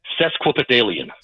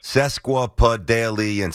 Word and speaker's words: sesquipedalian: Sesquipedalian. – Sesquipedalian. Six syllables.